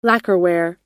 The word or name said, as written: lacquerware